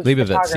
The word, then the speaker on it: Leibovitz: I want to say it's from, I might pronounce her name wrong, is Annie, Annie Lebetz, huge, huge. Le- Leibovitz, yeah.